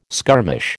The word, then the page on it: skirmish: American English